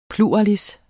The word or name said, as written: PLURALISSERNES